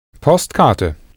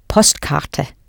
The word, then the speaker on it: Postkarte: Postkarte. – Ja, Postkarte.